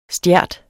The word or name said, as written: stjert